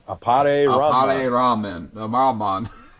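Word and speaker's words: Appare-Ranman: And then out of the new ones, I would say the one that I'm probably the most excited for is the Opera Ranman? Is that how you say it? I think Apare Ranman. – I always butcher these names. I'm so sorry, everybody. – Take it slow. Apare Ranman.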